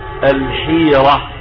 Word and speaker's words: الحيرة: سعيد بن جبير يقول سألني رجل من يهود الحيرة، وهكذا ينطق الاسم الحيرة، هكذا شكله ياقوت الحموي في معجم البلدان، سمى الحيرة غير الحيرة، الحيرة دي جبال، لكن الحيرة هكذا اسم لبلدة في العراق قرب الكوفة، اسمها ايه؟ الحيرة.